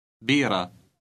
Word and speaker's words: بيرة: It ends with a silent T that is pronounced ah. Read number 20. – بيرة.